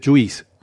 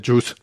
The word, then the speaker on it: juice: Y ya que estamos hablando de pronunciación, no se pronuncia Juice, se pronuncia Juice en inglés. Juice, vale. Juice, es como Chus, ¿vale? Como Chus Lampreave, ¿vale?